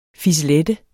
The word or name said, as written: fisselette